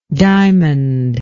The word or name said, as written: diamondlike